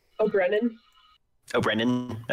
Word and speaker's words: Ó'Braonáin: By the way, how do you pronounce Maeve's last name? – Ó'Braonáin. – Okay.